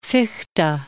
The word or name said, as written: Fichte